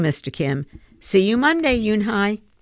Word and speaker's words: Yoon-hee: "Yes, it's pronounced Yoon-hee. And it means grace," Mr. Kim added. "Yoon-hee," Joey said slowly, and this time perfectly.